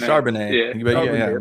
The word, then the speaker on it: Charbonnet: Chardonnay, is that how you say his name? Such a beautiful Charbonnet.